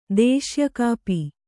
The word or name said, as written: ದೇಶ್ಯಕಾಪಿ